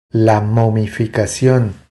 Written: La momificacion

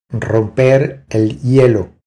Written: Romper el hielo

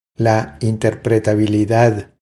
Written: La interpretabilidad

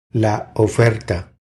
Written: La oferta

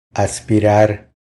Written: Aspirar

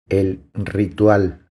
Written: El ritual

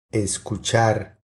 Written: Escuchar

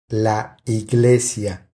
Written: La iglesia

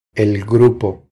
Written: El grupo

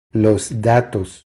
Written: Los datos

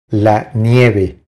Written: La nieve

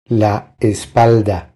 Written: La espalda

